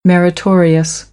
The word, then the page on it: meritorious: American English